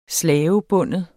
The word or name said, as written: slavebinde